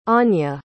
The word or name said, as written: Anja